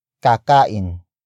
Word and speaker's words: kakain: Kakain. Kakain. Kakain is spelled as K-A-K-A-I-N.